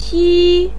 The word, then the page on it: ti1: pinyin